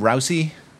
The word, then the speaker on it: Rousey: Rousey? – Yeah, sure. – And she's a UFC fighter, and Victor Ortiz is a boxer, isn't he? You know, I don't know, this thing. I mean, Ronda Rousey is apparently, like, her star is on the rise, and she's got a whole bunch of, like, mainstream movie stuff coming up.